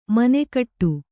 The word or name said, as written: ಮನಗೂಡು